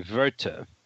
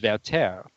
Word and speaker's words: Werther: Well, I think the German pronunciation would be Werther. But the opera is in French by Massenet, and so it's pronounced Werther.